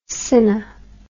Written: cinna

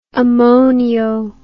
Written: ammonio

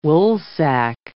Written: woolsack

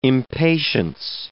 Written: impatience

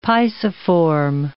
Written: pisiform

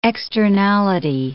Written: externality

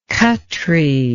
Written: Khatri

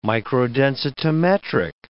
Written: microdensitometric

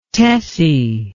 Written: tessie

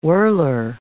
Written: whirler

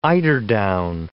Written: eiderdown

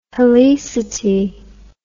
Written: helicity

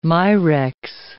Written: mirex

